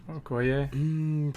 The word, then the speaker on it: Okoye: Okoye.